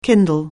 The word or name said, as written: kindle